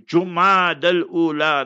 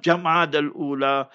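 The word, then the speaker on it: Jumada al-Ula: And when he, Nabi Adam alayhi salam, saw that his honorable wife, Eve, Sayyida Hawa, H-A-W-W-A, not Hawa, Hawa, learn to pronounce properly, Jumad al-Ula, not Jamad al-Ula.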